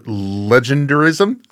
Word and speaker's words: legendarism: Well, you used a term, legendarism? Yes. Am I saying it right? – Yeah, you're saying it right, yeah.